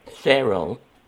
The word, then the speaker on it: Cheryl: Cheryl. – Cheryl? – Mm-hmm. – Can you spell that for me? – C-H-E-R-Y-L.